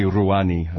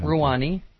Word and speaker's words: Rouhani: President Obama reportedly reached out to Iran's relatively moderate president, Hassan Rouhani. Am I saying that right? Probably. – Probably Rouhani. – Rouhani.